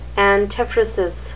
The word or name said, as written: antiphrasis